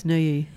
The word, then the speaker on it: Neuilly: I don't know why Corbu decided to do this, but on the Maison Jaoul, a pair of houses in suburban Neuilly. Did I pronounce that right? – Yes, Neuilly, yeah.